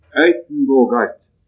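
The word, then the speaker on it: Uytenbogaert: Eitenbogart skal visstnok være bortimot riktig uttale på hollandsk.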